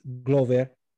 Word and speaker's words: Glover: Glover is the proper pronunciation.